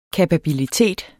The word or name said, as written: kapabilitet